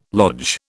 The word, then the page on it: lodge: British English